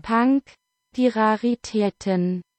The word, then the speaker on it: Punk die Raritäten: Again, in 2006, they put out Punk di Ratteratchen. I don't think I got that right. Punk the Rarities. There we go. So it's in German. – Punk die Raritäten. – There you go. That's a better German pronunciation than I.